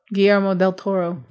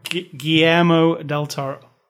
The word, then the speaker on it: Guillermo del Toro: Guillermo del Toro. – Guillermo del Toro.